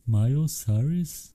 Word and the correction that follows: Miley Cyrus: Yeah, Miley Cyrus.